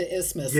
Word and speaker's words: isthmus: They say the original spelling with the TH was retained, but pronunciation just became to isthmus, yeah. – like asthma. Yeah.